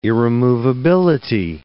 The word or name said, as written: irremovability